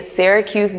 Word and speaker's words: Syracuse: So getting into your background, you lived in Syracuse, New York.